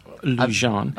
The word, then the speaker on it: Lujan: I am Jim Lujan, mm-hmm. pronounced Jim. And it's not pronounced Jim? – Lujan.